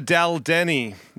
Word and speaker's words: Daldini: It is Louisa Daldini. Is that how I pronounce it? – Daldin. It's actually Italian, but it doesn't look it, does it? – Sounds like something out of Game of Thrones.